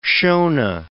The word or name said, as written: Shona